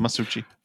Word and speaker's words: Masucci: Oliver Masucci. I'm not pronouncing that right. He actually utters line. – That's how he pronounces it anyway. – Oh, okay, good. – Masucci.